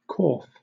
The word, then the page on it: corf: British English